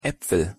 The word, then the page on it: apfel: German